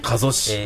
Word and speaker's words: 加須: 加須市、